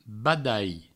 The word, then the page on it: badalh: badai; Provençal